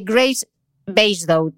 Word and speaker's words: Graves-Basedow: Aquí va mi pronunciación, que es la enfermedad de Graves-Basedow, ¿no? – Sí.